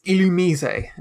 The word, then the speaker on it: Illumise: It's the Pokemon Illumise. – I can't even, because most people would pronounce it Illumise. – Yeah. – Or Illumise or Illumise. But it's Illumise.